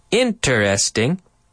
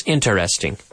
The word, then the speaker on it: interesting: Interesting. Interesante. Interesting. Da-da-da. Now, some people do pronounce this with four syllables, interesting. But it's much more common for native English speakers to pronounce it with three syllables. Interesting.